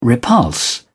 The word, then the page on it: repulse: British English